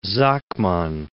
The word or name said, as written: Sakmann